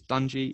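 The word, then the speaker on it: Dungey: And it's Eric Dungy, D-U-N-G-E-Y. I think it's Dungy. If it's spelt somewhere else, then I apologize.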